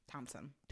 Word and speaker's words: Thompson: So Tristan Thomas. – Thompson. – Thompson. I always say his name. – Thomas? Thompson? – Thompson. Thompson. – Tristan Thompson. – There's a P.